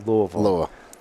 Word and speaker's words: Louisville: You gotta pronounce it right. It's Louisville.